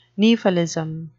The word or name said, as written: nephalism